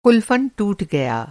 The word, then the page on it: क़ुलफ़न: قُلفن